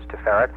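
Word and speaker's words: Tiferet: By the way, the way to pronounce that is Teferet. In Hebrew, the accent of any word will either be on the last syllable or the next to the last syllable. If it's a three-syllable word, it will never be on the first syllable.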